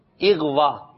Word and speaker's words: اغوا: اغوا یعنی الف کے نیچے زیر ہے, اغوا بھائی فلاں رشتے دار اغوا ہو گیا, اللہ نہ کرے یہ کوئی کہے کسی کے ساتھ یہ مصیبت آئے, تو صحیح تلفظ کیا ہے اغوا, اللہ کرے اغوا ہونے کے معاملات ہی ختم ہو جائیں, آمین.